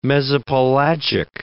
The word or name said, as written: mesopelagic